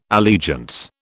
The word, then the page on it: allegiance: British English